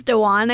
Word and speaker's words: Dewan: Hey, how do you say her name? – I think it's Dewan. I think you pronounced it right.